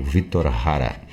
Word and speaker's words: Victor Jara: Uh, outros pronunciam Quilapayum, mas é Quilapajum, que trazia uma das vozes ali do grupo, o, o nosso Victor Jara, o Victor Hara,